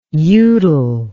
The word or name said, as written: uvedale